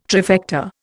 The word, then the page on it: trifecta: Indian